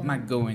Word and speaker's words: McGowan: Que foi pro BBB, é, Mac, eu não sei pronunciar o sobrenome dela, McGowan. – McGowan, isso. McGowan.